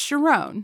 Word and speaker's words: Sharon: I don't Sharon? – Know. – You know him, the Prime Minister of Israel. – The Prime Minister of Israel. – He was. – Yeah. – Ariel Sharon. Yeah, it's Sharon.